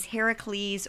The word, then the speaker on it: Heracles: Okay, so if we're talking about Greek heroes, the first Greek hero that comes to mind is Heracles or Hercules, however you want to say his name.